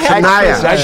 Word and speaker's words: Shania: Se apresentaram Aretha Franklin, Mariah Carey, Celine Dion, Gloria Stefan e a Shana Twain. – Adoro a Shania, Shania Twain. A, é a, é difícil esse nome.